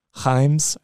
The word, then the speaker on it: Reims: Or I know also we've been seeing pop up here and there for the last 2 months of Ekitike from Rhyme. You know what? Ah, damn, I said that wrong. I know people on Sky Sports are saying it's not Reims, but Himes or for something like that for the French team.